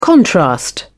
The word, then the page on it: contrast: British English